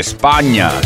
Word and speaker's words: Spagna: España. Se pronuncia así, pero se escribe Spagna.